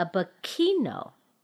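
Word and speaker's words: Biquinho: It's called a bikino. – Bikinto? – Bikino. – Oh, no T. Okay. – No T, but with a silent H.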